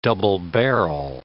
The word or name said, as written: double-barrel